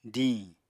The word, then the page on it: díiñ: apatani